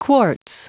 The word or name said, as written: quartz